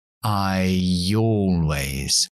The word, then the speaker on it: I always: Un sonido ya entre I y O. I always.